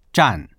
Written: zhan4

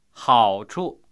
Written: hao3chu

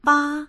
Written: ba1

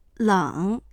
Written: leng3

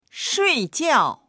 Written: shui4jiao4

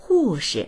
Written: hu4shi

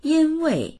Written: yin1wei4

